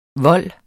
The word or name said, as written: VOLDENES